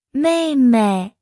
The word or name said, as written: 妹妹